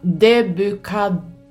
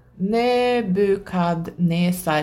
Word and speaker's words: Nebukadnesar: I Daniels bok finns det tre hänvisningar till klassen bevakare, helig och termen introduceras från någon som hette, nu ska vi se om jag kan uttala hans namn, Debukad Nebukadnesar. Nebukadnesar.